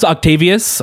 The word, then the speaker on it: Octavius: It's actually pronounced Octavius. – I just want to confirm it's pronounced but Octavius.